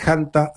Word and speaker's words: hantavirus: Se podría pronunciar también con la H muda, por supuesto. Y también es válida la pronunciación haciendo el énfasis en la primera sílaba con la letra J de Hanta, aunque también es válida la forma antivirus.